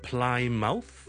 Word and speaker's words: Plymouth: P-L-Y-M-O-U-T-H 一个在南边海边的一个城市 P-L-Y-M-O-U-T-H 就不是读成呢是 Plymouth It's not Plymouth But it's Plymouth Plymouth Plymouth okay?